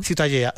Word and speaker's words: Ciutadella: Pero bueno, para mí la Ciutadella es... ¿cómo se pronuncia? – Ciutadella.